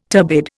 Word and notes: turbid: Indian

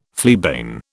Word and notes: fleabane: British English

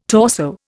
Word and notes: torso: Indian

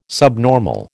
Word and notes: subnormal: American English

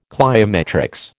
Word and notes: cliometrics: American English

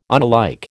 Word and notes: unalike: American English

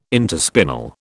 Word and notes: interspinal: British English

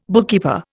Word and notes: bookkeeper: Indian

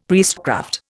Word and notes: priestcraft: Indian

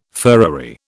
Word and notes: furriery: British English

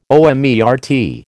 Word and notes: omertà: American English

